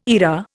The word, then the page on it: eyra: Indian